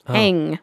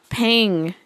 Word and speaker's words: Paeng: I kind of read it as pain, but it's got a G at the end. Pang. Oh. – Pang Mang. – Yeah, Pang.